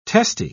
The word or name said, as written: testy